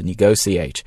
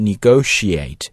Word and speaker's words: negotiate: Chief 在这里呢表示的就是首席的主要的啊，我们都知道 CEO, Chief Executive Officer, 这个 Chief 表示主要的，而 Brexit 这个词应该不陌生了，后面 negotiator, 这个单词呢其实来自于动词 negotiate, 那么这个词有两种读音啊，你可以读 negotiate, 你也可以读 negotiate, 就是 sh 和 s 这两个声音的区别。 So negotiate, negotiate, so negotiator, negotiator, negotiation, negotiation, both are fine, 啊，两种读音都是完全没问题的。